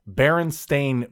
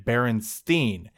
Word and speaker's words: Berenstain: I got in a screaming argument with a classmate because I insisted they were mispronouncing Berenstain because he was saying it Berenstein. A and I don't make an E sound, I shouted indignantly.